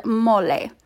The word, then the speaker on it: mole: And there are actually two origin stories to the word mole, which is pronounced mole. It's spelled M-O-L-E. So in, you know, the English language, we would pronounce it mole, but we're not talking about English here.